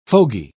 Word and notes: fogy: British English

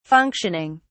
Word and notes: functioning: British English

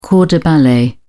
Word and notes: corps de ballet: British English

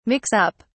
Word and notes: mix-up: British English

